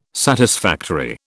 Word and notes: satisfactory: British English

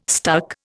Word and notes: stuck: Indian

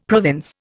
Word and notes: province: Indian

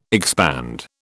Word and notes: expand: British English

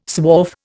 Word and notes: swarf: Indian

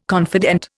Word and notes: confidant: Indian

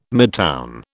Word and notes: midtown: British English

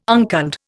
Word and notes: unculled: Indian

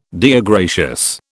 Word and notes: Deo gratias: British

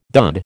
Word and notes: dud: American English

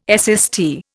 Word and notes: SST: Indian

